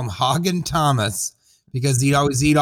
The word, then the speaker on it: Haugen: It's by Thomas uh, H A U G E N. Uh, how would you pronounce that? Hagen.